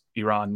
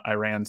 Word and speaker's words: Iran: And the Iranian press member goes, first of all, you're pronouncing your country wrong. It's pronounced Iran, not Iran.